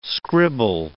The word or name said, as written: scribble